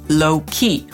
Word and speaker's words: low-key: Jego imię akcentujemy na pierwszą sylabę, a więc mówimy low-key, a to słowo zaakcentujemy raczej na drugą, czyli low-key, choć nie zawsze będzie to bardzo dobrze słychać. I teraz co ma na myśli młodzież, kiedy mówi na przykład, że ktoś jest low-key embarrassed?